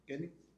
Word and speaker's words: Kenny: Kenny. En Facebook estoy como Kenny Rodríguez.